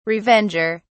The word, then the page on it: revenger: British English